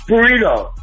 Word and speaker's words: burrito: Oh, burrito. – Yeah. All right. – Okay. – Don't forget to roll your R's.